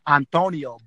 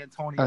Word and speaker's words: Antonio: Well, you know, in a Latin culture, it's Antonio, but in America, they say Antonio.